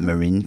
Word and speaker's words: Marin: Marin County, yeah. – No, Marin County. – Oh, got the emphasis on the wrong syllable.